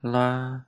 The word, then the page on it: la: Lwaitel